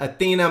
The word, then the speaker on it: Athyna: Life Unapologetic with Athena. My name is spelled A-T-H-Y-N-A.